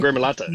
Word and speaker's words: gremolata: Yep, lemon gremolata.